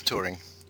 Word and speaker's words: Thuring: It's Thuring. – Thuring. Oh, I've been pronouncing it Thoring to my friends, yeah. but Thuring. – No problem. – And Silent H.